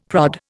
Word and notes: prod: Indian

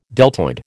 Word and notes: deltoid: American English

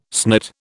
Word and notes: snit: British English

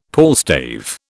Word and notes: palstave: British English

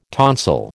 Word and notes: tonsil: American English

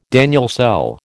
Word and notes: Daniell cell: American English

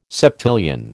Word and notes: septillion: American English

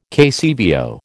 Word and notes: KCVO: American English

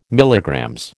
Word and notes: MG: American English

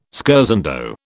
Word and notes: scherzando: British English

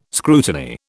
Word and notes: scrutiny: British English

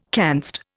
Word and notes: canst: Indian

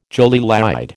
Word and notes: jolie laide: American English

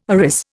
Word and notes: arris: Indian

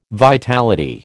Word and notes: vitality: American English

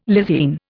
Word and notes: lysine: Indian

